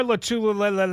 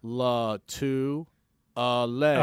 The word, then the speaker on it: Lotulelei: So it's Star Latu-a-lay. – Latu-a-lay. – Okay. – Four syllables. Latu-a-lay.